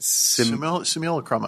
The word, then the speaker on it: simulacrum: I mean, a lot of this also taps into the Wachowskis made everyone read Smuk how do you say it? – Simulacrum, I believe. – Yes, simulacrum.